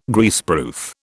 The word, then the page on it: Greaseproof: British English